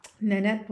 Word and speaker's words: Nanette: Nanette Bulabash.